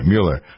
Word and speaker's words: Mueller: Mueller. I always remember the Mueller's egg noodles. That's how I pronounce this guy's name.